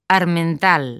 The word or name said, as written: Armental